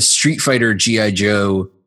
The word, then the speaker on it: Street Fighter X G.I. Joe: Joe was writing the Street Fighter G.I. Joe. The X is silent. It's spelled Street Fighter X G.I. Joe if you're looking for it, but that's, we don't say it.